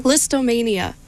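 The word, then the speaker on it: Lisztomania: Listomania by Phoenix.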